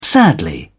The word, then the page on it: sadly: British English